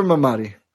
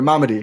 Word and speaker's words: Mamadi: Good for Mamadi. Or Mamadi, excuse me. I don't know why I always say it wrong.